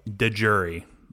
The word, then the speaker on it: de jure: That is an act of de jure. I don't know how to pronounce it. – De jure is that. That's how it was pronounced.